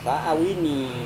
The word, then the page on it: awini: Serewen